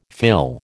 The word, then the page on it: fill: American English